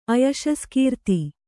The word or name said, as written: ಅಯಶಸ್ಕೀರ್ತಿ